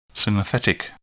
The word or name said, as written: synthetic